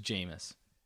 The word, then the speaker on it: Jameis: Is it Jameis or Jamie's? – Fuck divine. – I've man. – Heard people say both, and I think it's just Jameis.